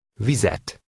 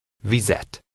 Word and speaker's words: vizet: Vizet. Et. Et. Víz. Vizet. – Note that this word has a shorter vowel sound and adds a linking vowel plus the object ending T.